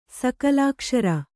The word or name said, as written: ಸಕಲಾಕ್ಷರ